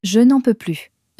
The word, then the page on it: je n'en peux plus: French